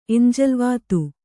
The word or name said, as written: ಎಂಜಲ್ವಾತು